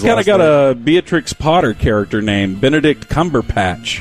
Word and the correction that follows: Cumberbatch: And he goes, actually, it's Cumberbatch. – That's right. He's very gracious about it.